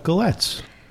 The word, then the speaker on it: galette: It's a French thing, but it is pronounced the way it's spelled, G-A-L-E-T-T-E. I checked, I quadruple checked that on four different sites with pronunciations. So it's not like Merlot looks like Merlot.